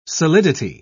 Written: solidity

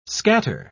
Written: scatter

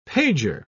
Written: pager

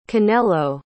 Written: canelo

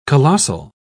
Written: colossal

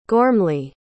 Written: gormly

